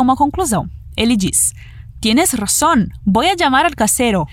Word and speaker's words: razón: O R vibrado, o som de S e o N no final com a pontinha da língua no céu da boca. Razón.